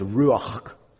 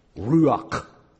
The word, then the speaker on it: ruach: Well, like the Greek word, the Greek synonym, this Hebrew word, it's a fun word to say, because you got to get that real guttural sound at the end. It's ruach, ruach, ruach.